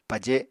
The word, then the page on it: paje: Apatani